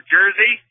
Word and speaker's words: Jerzy: Father Jerzy, like football jersey. That's how we pronounce it.